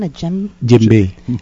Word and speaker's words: djembe: Djembe.